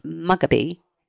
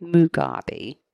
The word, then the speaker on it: Mugabe: They gained independence from Britain and Robert Mugabe. – Or Mugabe. – Is that how you say it? Mugabe. Should have known that.